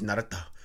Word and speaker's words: Naruto: If you get the Japanese subtitles, it's Naruto. It's not even. They don't even really pronounce the O. It's Naruto.